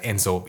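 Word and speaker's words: Ezo: Sin embargo, haciendo un paréntesis aquí en contorno a los Emishi, que también se puede pronunciar Enzo, y eso ha llevado a la confusión o a la creencia de, de trazarlos en similitud con los Ainu, pero son personas distintas.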